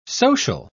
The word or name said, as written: social